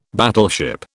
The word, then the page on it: battleship: British English